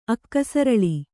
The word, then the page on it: ಅಕ್ಕಸರಳಿ: kannada